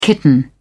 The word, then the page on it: kitten: British English